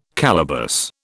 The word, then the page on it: calaboose: British English